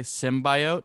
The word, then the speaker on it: symbiote: I've been saying it like with the emphasis like that, and then I heard somebody say symbiote, and I was like, "Is that how you're supposed to pronounce that?"